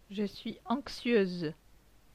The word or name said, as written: Je suis anxieuse